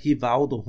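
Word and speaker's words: Rivaldo: til at lære Lars Jacobsen, hvordan man udtalte navnene, ja, Hivaudo, Honaudo og så videre.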